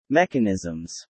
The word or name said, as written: mechanisms